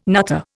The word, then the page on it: nutter: Indian